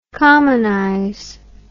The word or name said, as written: commonization